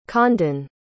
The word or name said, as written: condon